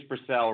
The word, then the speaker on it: Brassell: It's Brassell, Rory, but don't worry about it. Nobody gets it right.